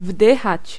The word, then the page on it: aspirer: /as.pi.ʁe/